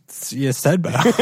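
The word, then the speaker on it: Ydstebø: Here's the spelling of his last name. Y-D-S-T-E-B-O. – Yeah. Yudstedbo.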